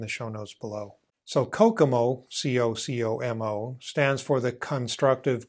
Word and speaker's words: Kokomo: A while ago, Barry Boehm created a tool called Kokomo. Yeah, it's pronounced like the Beach Boys song, but it is unfortunately not a Caribbean island where you can go to get away from it all.